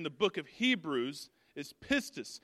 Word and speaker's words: pistis: It's pistis. And believe me, I listened to it like 10 or 15 times because I wanted to make sure that I said it right. It's pistis,